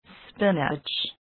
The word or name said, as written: spinach